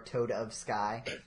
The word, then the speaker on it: Toadofsky: That's how you would pronounce it, right? Not Toadofsky or Toad of Sky.